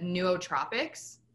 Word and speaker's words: nootropics: I always butcher how to pronounce this, so bear with me, but nootropics. – Okay. – Did I say it right? – I think so, yeah.